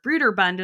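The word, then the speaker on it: Brøderbund: And despite it sounding vaguely Scandinavian, Broderbund or Bruderbund as they originally pronounced it, but over time, like no one pronounced it that way, doesn't actually really mean anything.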